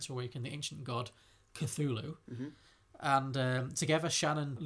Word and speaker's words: Cthulhu: Cthulhu, thank you, Harry.